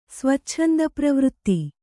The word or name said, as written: ಸ್ವಚ್ಛಂದಪ್ರವೃತ್ತಿ